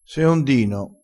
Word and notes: seondino: dialettale

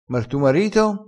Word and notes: e quer: dialettale

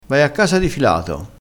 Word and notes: di filato: dialettale

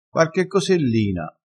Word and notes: cosellina: dialettale

